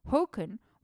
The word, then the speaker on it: Håkon: So once again, Håkon is spelled Håkon, but you pronounce the A with a little chukar over it as an O. So Håkon was a naval inspector at Lofoten at the mouth of the Indalsälven.